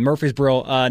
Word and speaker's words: Murfreesboro: BYU will make a return trip to Murfreesboro. Did I say that correctly? – Yes, sir. – Yeah, Murfreesboro next season.